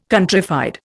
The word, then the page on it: countrified: Indian